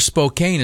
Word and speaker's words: Spokane: This one is out in Spokane, Washington. Or Spokane, as I believe they pronounce it there.